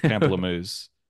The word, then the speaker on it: pamplemousse: You're calling it pampoosie, but I would say it's pamplemousse.